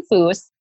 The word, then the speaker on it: Fuhs: I am Ashton Fuhs.